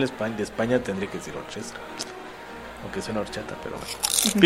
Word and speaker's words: orchestra: Recuerde que cuando pronuncias en inglés algo que tenga una CH es como una K, entonces sería orquestra. – Orquestra, pero yo creo que dice orquestra.